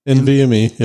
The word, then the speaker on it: NVMe: Joe's not going to care, but 4 terabyte NVMe 2. Wait, did I say that right? – NVMe. Yep.